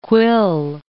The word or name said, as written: quill